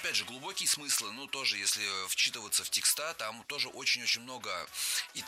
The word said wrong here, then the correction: тексты: Вчитываться нужно не в текста, а в тексты. Слово «текст» тоже имеет неподвижное ударение на основе, поэтому всегда ударяем первую «е».